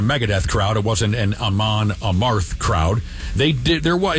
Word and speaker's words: Amon Amarth: Amon Amarth is how you say it.